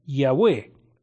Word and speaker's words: Yahweh: En hebreo, el nombre personal, principal de Dios, que es Jehová, se escribe con cuatro consonantes, YHWH. Al añadir vocales, se pronuncia algo así como Yahweh o Jehová.